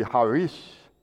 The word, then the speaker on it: charis: Our English word grace comes from a Greek term, usually pronounced charis. I understand it really should be charis, C-H-A-R-I-S.